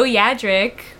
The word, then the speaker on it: Yadrick: Joe Yadrick, that's my Instagram. You can find me there. It's exactly how it's spelled exactly how it sounds.